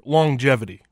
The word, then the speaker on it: longevity: So he sent me a TikTok about the word, I wish I had a pen and paper, longevity, right? The word longevity. – Uh-huh. – Why do we pronounce the G in that word twice? Longevity. Longevity.